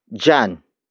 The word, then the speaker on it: diyan: And that is jan. Jan. Jan. Jan is spelled as D-I-Y-A-N.